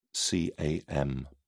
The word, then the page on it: cam: British English